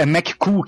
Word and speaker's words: McCool: é na verdade é, é McCool que se pronuncia. É. Cool de legal, tipo em inglês, é ah, é bem legal.